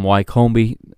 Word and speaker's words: Wycombe: I apologize for butchering the pronunciation of Wycombe. Last week I called them Wycombe in one of my most gringo moments ever. It is Wycombe Wanderers, so it is today, July the 13th, 2020, at 2:25 Eastern Time on ESPN+.